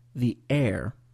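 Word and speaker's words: heir: On dit the air. The air. Et ce mot en français, ou pas en français, en anglais, a un H muet au début. Alors, c'est H. E-I-R, c'est l'autographe.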